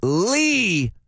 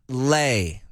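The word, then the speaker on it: Leigh: She says, it's Lee, not Lay. That street off ramp comes up a lot in our traffic reports, and it's L-E-I-G-H, but it is pronounced Lee.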